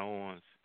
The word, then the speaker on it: Owens: My name is Kijana Owens.